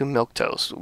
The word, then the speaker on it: milquetoast: Yeah, you do need someone like that on the series, but they made her a little too milquetoast. Milk? What is it? Milk? How do you pronounce it? – Milquetoast. It's right. Like, milquetoast.